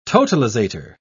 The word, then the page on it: totalizator: British English